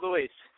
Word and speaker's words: Luis: Yes, this is Luis.